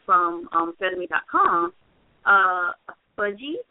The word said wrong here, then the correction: Budgie: which I don't know if I'm saying that right, but it's B-U-D-G-I-E. I don't know if that's correct or not. – Yeah, Budgie. Yeah, that's Budgie, which is so cute.